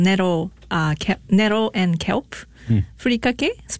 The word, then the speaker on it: furikake: The furikake, is that how you pronounce it? – Yes, furikake, and a dry seasoning.